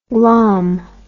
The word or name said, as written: glahm